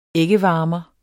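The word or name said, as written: æggevarmere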